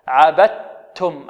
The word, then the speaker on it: عبدتم: وعندنا هنا الدال أدغمت في التاء إدغاما كاملا، عبدتم، النطق بتاء مشددة، نخرج من الباء إلى تاء مشددة، عبدت، عبدتم، عبدتم، ولا أنتم إخفاء، عابدون ما أعبد، لكم دينكم ولي دين، ديني، يعني النون مكسورة.